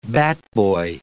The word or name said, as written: batboy